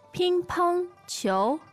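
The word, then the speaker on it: Ping pong qiu (乒乓球): Ping pong qiu. Ping pong qiu. – Ping pong chou. Okay, so it's first tone, and second tone.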